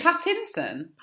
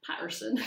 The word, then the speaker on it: Pattinson: Is it Pattinson? – Pattinson? – Oh, God, I've been pronouncing that wrong as well. – Why did you say Patterson? – Robert Patterson.